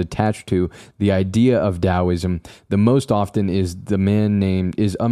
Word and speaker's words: Daoism: Regardless of how you spell it, it should always be pronounced with a D sound. So it isn't Taoism, it's Daoism.